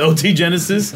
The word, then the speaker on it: OT Genesis: I was going OG Genesis. OT Genesis.